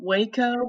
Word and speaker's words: WEHCO: It's a company called Waco, which it's spelled W-E-H-C-O, Waco Media Incorporated.